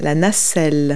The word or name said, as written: gondola